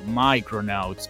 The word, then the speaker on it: Micronauts: Micronauts, o Micronauts, que es como se pronuncia.